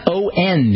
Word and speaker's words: On: All right, let's go to California, O-N in California.